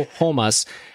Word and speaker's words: Ramos: Ricardo Ramos got Ricardo Homas, I'm going to say it correctly, got the victory.